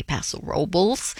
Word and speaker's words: Paso Robles: Windfall Farms is a 700-acre winery in Paso Robles, California, although the locals say Paso Robles.